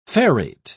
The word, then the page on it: ferrate: British English